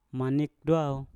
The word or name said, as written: manip dwau